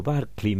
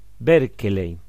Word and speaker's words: Berkeley: Los que no sabemos inglés no podemos pronunciarlo correctamente algo así como Barclay me parece que es, George Berkeley,